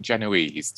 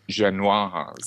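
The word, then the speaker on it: génoise: And she says, prepare a, I don't know whether you pronounce it, some people pronounce it Genoese, don't they? And if you're being very French, if you've actually got a Paris balcony, then you would pronounce it Genoise.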